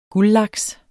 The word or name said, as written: guldlaks